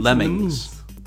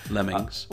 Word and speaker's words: Lemmings: Lemonines, Le- Lemines, Lemines, Lemmings. – Really? – Lemmings.